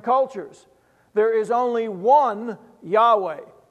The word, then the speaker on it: Yahweh: It's in the Hebrew, it's the word, it's spelled YHWH. It's pronounced Yahweh is our God.